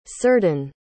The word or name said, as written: círdan